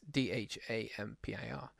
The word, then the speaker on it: Dhampir: In the film, Blade is a dampire. Dampier? How do they pronounce it? – I just wanted to just vampire. – It's Dhampir, a human with vampire strengths,